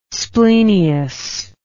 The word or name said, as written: splenius